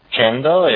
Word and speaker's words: Gendo: Yeah, Gendo, G-E-N-D-O. – Gendo, yeah? – Yeah, Gendo. It's a hard G. – Gendo, yeah. – G-E-N-D-O dot net.